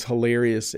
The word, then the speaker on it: hilarious: This is a hard word for English learners to pronounce sometimes because the H is sort of said hilarious. I'm overpronouncing it now. Hilarious. Hilarious. The H is in the back of my mouth.